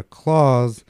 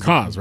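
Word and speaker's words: KAWS: He sold stuff from his private collection, and he had a Claus.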